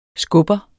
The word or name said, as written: skubber